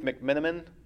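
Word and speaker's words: McMenamin: And I know, actually, they were talking about he's touched base with Dave McMiniman. Is that how you pronounce his name? – I believe so, yeah.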